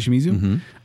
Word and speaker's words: Takashi Shimizu: And this is directed by, I'm not going to say his name right, Takashi Shimizu? – I feel like that's pretty good. – Takashi Shimizu?